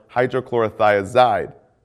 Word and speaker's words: hydrochlorothiazide: Potassium wasting ends in "-ide," like furosemide or hydrochlorothiazide. Both rhyme with dried.